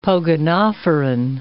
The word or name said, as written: pogonophoran